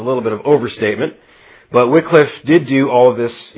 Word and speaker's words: Wycliffe: The name John Wycliffe is pronounced with a short I, but it's spelt W-Y-C-L-I-F. Sometimes F-F, sometimes F-F-E, sometimes F-E. It, seriously, even in his own day, it went by various spellings. But it's pronounced Wycliffe.